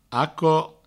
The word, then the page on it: aquò: aco